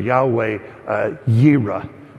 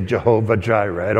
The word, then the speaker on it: Yahweh Yireh: Or Yahweh Yireh, which some people say Jehovah Jireh. I don't pronounce it that way.